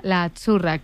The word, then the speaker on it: azzurra: Azzurra. Sí, como porque pizza, la Z no es la Z como decimos en catalán tan sonora, no. Ah, es azzurra. – Muy bonito, muy bonito. – Es como pizza.